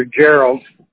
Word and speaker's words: Gerald: My real name, I go under Gerald.